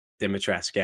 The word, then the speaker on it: Dimitrescu: Yeah, I found Dimitrescu. I feel like the U is silent, I've heard. – Okay, Dimitrescu. Yeah, that feels better to say, actually.